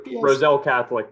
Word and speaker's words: Roselle Catholic: So Corey Floyd Jr. played at Roselake Catholic. Correct me if I'm saying that wrong. – Roselle Catholic. Come on. I'm from Jersey. You can't be doing that to me. – Okay. Roselle Catholic.